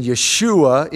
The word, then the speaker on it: Yeshua: I know I mentioned it once, and maybe this person heard it elsewhere, that the name Jesus really was not his name. That's a very Americanized pronunciation of his name. His name would have been Yeshua in Hebrew.